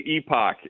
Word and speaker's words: Epoch: So I would say Epoch, yes, Epoch TV, yeah. But I think that both work in, you know, because everybody has got a different pronunciation for right.